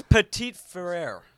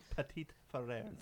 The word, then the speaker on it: Petit Frere: It's Petit Frere. Let's say it right. – Petit Frere.